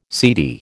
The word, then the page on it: seedy: American English